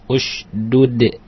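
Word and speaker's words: Usdud (اشدد): Ini bacanya gimana sih? – Iya. Usdud bacanya gitu.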